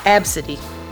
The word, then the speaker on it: Abcde: So Southwest Airlines apologizes to a mom after a gate agent made fun of her daughter's name, spelled A-B-C-D-E, pronounced Absidy.